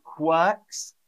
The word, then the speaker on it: quirks: And then I had another question from Diana, how to pronounce the word quirks. Quirks. So we don't say the R. And the R, I and R in English often becomes like an R sound.